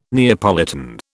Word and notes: Neapolitan: British English

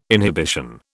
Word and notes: inhibition: British English

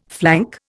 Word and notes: flank: Indian

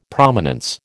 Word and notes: Prominence: American English

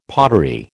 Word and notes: pottery: American English

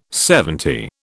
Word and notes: LXX: British English

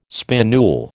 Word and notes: spinule: American English

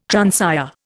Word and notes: transire: Indian

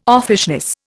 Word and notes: offishness: Indian

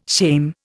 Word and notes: shame: Indian

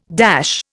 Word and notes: dash: Indian

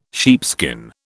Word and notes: sheepskin: British English

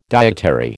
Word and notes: dietary: American English

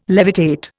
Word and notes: levitate: Indian

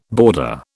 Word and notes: border: British English

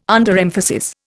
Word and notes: Underemphasis: Indian

